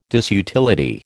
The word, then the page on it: disutility: American English